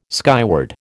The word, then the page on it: skyward: American English